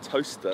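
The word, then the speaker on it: Towcester: So you're originally born in, is it how you say it? Like Tochester or? – I'm Toaster. – Toaster. Oh, that's how you say Toaster. – Like a toaster, yeah.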